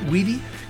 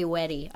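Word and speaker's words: WEDI: I take no responsibility for the mispronunciation of that E, but people do say weddy. I've heard it before, and it's totally understandable.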